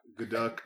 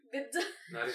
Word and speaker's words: Gadot: Because that's how they say her name. She got Gadot. – Gadot.